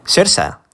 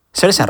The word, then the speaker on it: Saoirse: Porque, porque está la actriz cuyo nombre se escribe Saoirse Ronan, que se pronuncia Sersa. Sersa Ronan.